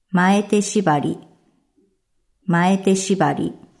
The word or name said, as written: Mae te shibari